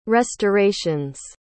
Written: restorations

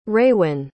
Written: raewyn

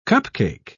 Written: cupcake

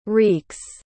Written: reeks

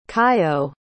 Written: kaiyo

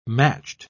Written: matched

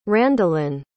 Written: Randalynn

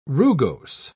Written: rugose

